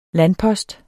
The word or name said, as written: landpost